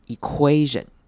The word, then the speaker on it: equation: 那注意到下一个字是 equation，这个字要注意的是中间的 UA 要念成 QA，念成 U 的声音再念成长母音 A，最后念成 equation， equation，然后重音要放在 UA 这边上面， equation， equation，后面 TION 如往跟以前一样念成人的声音， equation， equation。